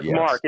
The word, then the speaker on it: Marc: Marc, M-A-R-C, Sims, S-I-M-S.